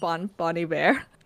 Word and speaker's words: Bon Iver: Oh, by the way, did you know it's pronounced Bon Iver? – Bon Iver? No, I did not know that. – It's apparently supposed to be French.